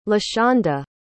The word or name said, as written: lashanda